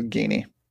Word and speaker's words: Geaney: I think it's Geeney. Do you know that? How to pronounce that name? Geeney? – I have no idea. – G-E-A-N-E-Y, the Padres farm director. Oh, okay. Geeney. Sounds right.